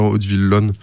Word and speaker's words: Hauteville-Lompnes: En fait, ça s'écrit Hauteville-Lompnes, mais il faut dire Hauteville-Lonne.